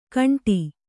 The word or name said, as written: ಖಂಡಿ